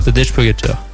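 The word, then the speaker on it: Tadej Pogačar: Tadej Pogacar. Tadej Pogacar.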